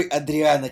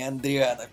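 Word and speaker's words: Адриано: Один, во-первых, Федерико Филини, а не Фредерико, а другой Адриана Челентана, а не Андриана.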